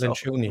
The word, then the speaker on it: Lencioni: wie spricht man den aus, Leoncioni-Modell äh, geguckt und ich nutze das Lencioni, auch.